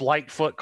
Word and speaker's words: Lightfoot: It's Lightfoot Clan. – There you go. – And light it's spelled is in out. Light. Yeah, light as in light switch, and then foot as in foot, and then clan.